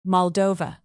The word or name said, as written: moldova